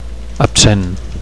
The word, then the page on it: absent: Catalan